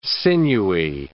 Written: sinewy

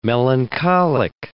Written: melancholic

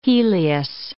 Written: helios

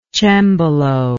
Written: cembalist

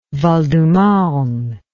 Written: Val-de-Marne

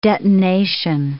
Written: detonation

